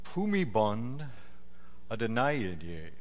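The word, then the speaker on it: Bhumibol Adulyadej: Actually, it's pronounced not like it looks. It's Pumibon Adonaiye.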